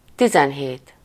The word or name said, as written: dix-sept